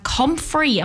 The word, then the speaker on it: comfrey: I have comfrey. Am I saying that right? Comfrey. – Yes.